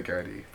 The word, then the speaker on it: Garda: Am I saying it right? Garda? Yeah, Garda. Some people say Gardaí, but Garda's fine.